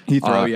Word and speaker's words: Heathrow: Heathrow, yeah.